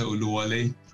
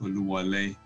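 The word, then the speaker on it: Olúwọlé: The name is pronounced Olúwo. The second O has a dot underneath it, means God entered the house. If you take the dot out, it means God destroys the house.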